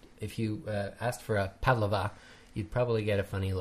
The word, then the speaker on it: Pavlova: The stress is in the first syllable, not Pavlova, but Pavlova.